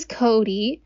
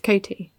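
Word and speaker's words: Coty: His identical twin brother, Cody, but it's C-O-T-Y. – Our accents, the way we pronounce our T's, water. We say, yeah. We pronounce our T's as more that D.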